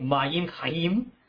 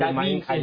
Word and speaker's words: Mayim Chayim: I'm probably going to pronounce it incorrectly, but Mayim Chayim. – 它的发音就是地球的发音 Mayim Chayim.